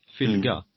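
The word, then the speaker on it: fylgja: Eh, kapitlet efter det är ju att man ska hitta sin fyldja, uttalas väl det Fylga.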